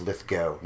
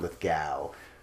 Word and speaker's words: Lithgow: John Lithgow is barely in the movie. He's very good. And by the way, if you ever listen to this podcast, hey, John, it's Lithgow, not Lithgow.